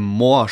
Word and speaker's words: Morr: Und auch ähm, Moore spricht man ihn, glaube ich, aus, also M-O-R-R.